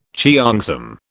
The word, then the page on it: cheongsam: British English